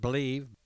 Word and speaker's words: BLEAV: BLEAV is how they spell it, Believe.